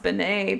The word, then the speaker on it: Beane: Yeah, so some people spell her name B-E-A-N-E, which I assume is the correct way. – Oh boy. – And you know it's pronounced Benet because the other way to spell it is like B-E-A-N-E-Y, mm. right?